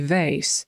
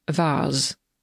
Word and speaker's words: vase: Tutaj wyrocznia mówi vase i to jest wazon, ale jest to wymowa amerykańska. Brytyjczyk powiedziałby vase i to jest dokładnie to samo słowo. Tak samo się pisze vase przez V.